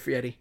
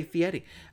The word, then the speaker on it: Fieri: I've given you the respect, because when we first started mentioning this on the show, I wouldn't even say Fieri. I would say Fieri, because it looks like Fieri to me. It's spelled Fieri.